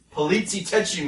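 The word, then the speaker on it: poliziotteschi: Well, from there, we're gonna talk about Meet Him and Die, which is the latest release from Rare Video, a Polizzi Tetchi movie. – Oh, you said it right.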